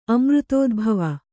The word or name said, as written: ಅಮೃತೋದ್ಭವ